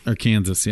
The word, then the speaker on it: Arkansas: Arkansas. Yeah, that's how they say it, I think.